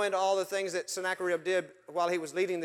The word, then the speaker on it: Sennacherib: And they were led by a guy by the name of Sennacherib. I always called him Sennacherib. Snacking on a rib. That's how you remember how to say his name. Sennacherib.